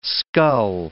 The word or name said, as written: scull